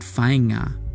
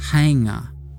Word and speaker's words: whainga: You'll hear the word whainga. It's another kupu used for tūpuna ki Ngāpuhi, and sometimes pronounced hainga. – I ngā mātua whainga, tēnei te mihi atu te tangi atu.